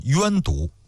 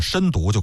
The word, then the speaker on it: 身毒: 关于这个读音呢，有好几种说法，有些书上包括央视的纪录片上把它读成是冤毒，但是还有一个专门的文章啊，就说央视里边这个冤毒呢是读错了，就直接读作身毒就可以了。